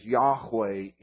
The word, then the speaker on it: Yahweh: which is pronounced as Yahweh in English, which today is almost universally recognized by biblical scholars as the closest, and I didn't spell that word right.